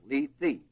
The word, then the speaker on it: Lethe: And one of those rivers is called the Lethe, L-E-T-H-E, or Lethe, I guess, long E. Would it be Lee? Yeah, Lethe, long E.